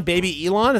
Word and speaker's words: Babylon: It's spelled Babylon, but it's supposed to be, I guess, Bab Elon, Baby Elon.